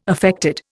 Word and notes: affected: Indian

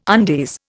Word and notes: undies: Indian

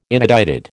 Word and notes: inedited: American English

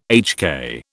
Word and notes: HK: British English

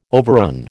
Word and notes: overrun: American English

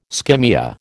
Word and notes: skimmia: American English

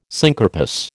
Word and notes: syncarpous: American English

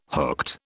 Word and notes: hooked: British English

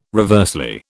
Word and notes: reversely: British English

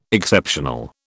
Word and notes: Exceptional: British English

